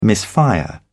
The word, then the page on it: misfire: British English